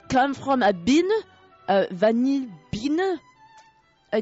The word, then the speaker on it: vanille: You know, in France, if you have two L, it's pronounced like a why. – Vanille.